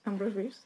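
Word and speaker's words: Ambrose Bierce: Entonces parece que tienes razón, nuestro nuevo señor favorito, que es ¿cómo se dice? Ambrose Beers. Ambrose Beers, sí.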